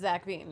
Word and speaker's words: Veen: It's like it rhymes with vaccine. – Because it's like vaccine, but it's Zach Veen, yeah.